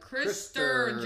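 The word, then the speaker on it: Krister: Is Christopher? No, not Christopher. That's not how you say it. Christer Johnson and like a million other people directed it, produced it, wrote it.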